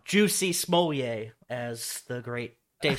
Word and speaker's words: Jussie: Dude, how do you say her his name? I think that's how Ugh. you say it. – Juicy, juicy, juicy.